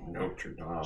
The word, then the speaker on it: Notre Dame: Excuse me, Notre Dame. – So I assume you've never watched that movie. – I have watched it. – You don't know how to pronounce it, though. This isn't the football team.